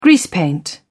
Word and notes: greasepaint: British English

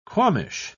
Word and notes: qualmish: British English

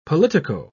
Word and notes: politico-: British English

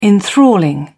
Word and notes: enthralling: British English

